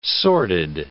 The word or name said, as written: sordid